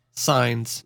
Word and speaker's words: Cines: On January 30th, 1990, 3 months before the CBN bombing, Joel Osteen's daughter, Lisa Sines, C-I-N-E-S. How would you say that? Lisa Sines? It wouldn't be a hard C, would it? Kins? Kines? I don't think so. – Sines.